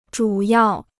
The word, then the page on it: 主要: zhǔ yào